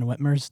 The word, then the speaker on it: Whitmer: Isn't that Gretchen Whitmer's territory? – It is. Governor Whitmer. Yeah, I said it right.